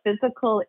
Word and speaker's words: Fyzical: So Physical first, for your audience who are not familiar with us, Physical is spelled F-Y-Z-I-C-A-L, and it's Physical Therapy and Balance Centers.